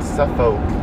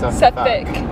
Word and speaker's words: Suffolk: It's spelled Suffolk, but it's pronounced Suffolk.